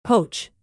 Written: poach

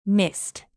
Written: mist

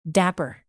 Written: dapper

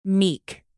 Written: meek

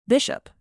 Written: bishop

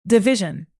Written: division

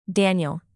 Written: daniel

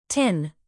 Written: tin

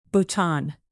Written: bhutan

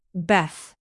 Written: beth

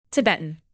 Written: tibetan

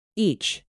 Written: each

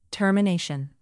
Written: termination

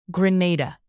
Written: grenada

